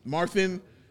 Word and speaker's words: Mathurin: The rookie on the Pacers, Mathurin.